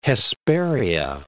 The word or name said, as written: Hesperia